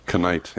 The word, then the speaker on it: Knight: So if I say the names Phil and Penny Knight with a K, because in our English language, the K is silent, because that's a fun time. – Knight, yeah.